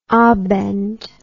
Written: abend

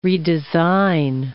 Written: redesign

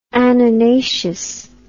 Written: anonaceous

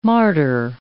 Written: martyr

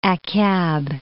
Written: Akyab

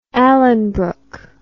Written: Alanbrooke

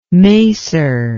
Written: macer

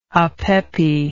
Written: apepi

